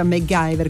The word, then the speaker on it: MacGyver: Allora, il Mangio, intanto benvenuto, dice giustamente si pronuncia MacGyver,